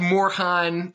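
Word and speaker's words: Morejon: but back to Morehan, Silent J, obviously.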